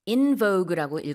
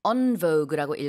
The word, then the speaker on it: en vogue: In vogue라고 읽어도 되고요. En vogue라고 읽어도 되는데 다시 유행하고 있어요.